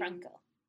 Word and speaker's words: Frankl: By Ludwig August Frankl is another. And I the colors are seen. – Frankl. You gotta retract all the syllables.